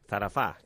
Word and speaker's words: Zarafa: Zarafa, que és el títol d'aquesta pel·lícula, fem la pronunciació francesa, no?